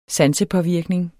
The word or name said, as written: sansepåvirkning